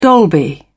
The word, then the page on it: dolby: British English